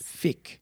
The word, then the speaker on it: fiqh: Fiqh. – How do you? – F-I-Q-H. – Q-H. – Yes. – Fiqh.